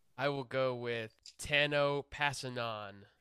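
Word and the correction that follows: Kpassagnon: The K is very quickly acknowledged in his pronunciation, though. It's Kapasanyan.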